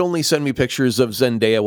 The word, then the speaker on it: Zendaya: Okay, so maybe Theatrical Forever pointed out that I had mispronounced Zendaya's name and said, by mistake and force of habit, Zendaya.